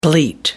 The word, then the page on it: bleat: American English